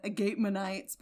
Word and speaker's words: Agapemonites: I would go, it's spelled like agape, A-G-A-P-E, monites, M-O-N-I-T-E-S. I would go with something like, I'm American, I'd go with Agapemonites, but probably something that sounds like Agapemonotes.